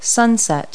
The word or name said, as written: sunset